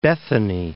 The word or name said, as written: Bethany